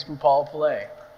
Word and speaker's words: Poopalapillai: For those of you who don't know, my last name is Poopalapillai, And people often pronounce it and botch it, and that's completely fine.